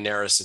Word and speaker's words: Narus: My name is Ryan Narus. It's spelled N-A-R-U-S. That's N like nurse.